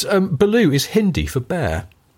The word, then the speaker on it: Baloo: It's actually spelt differently. B-H-A-A-L-O-O. It would be the spelling in Hindi, but it's the same pronunciation. So, yeah, Baloo just means bear in Hindi.